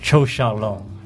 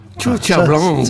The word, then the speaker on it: Qiu Xiaolong: The Chinese way is Chou Xiaolong. – Chou Xiaolong. – Ça, c'est la prononciation chinoise.